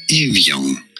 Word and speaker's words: Evian: So for reference, in French, this is said as Evian.